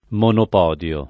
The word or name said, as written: monopodio